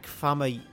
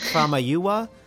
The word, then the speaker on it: Famuyiwa: Is Rick Famuyiwa. I butchered it last time. Rick Famuyiwa.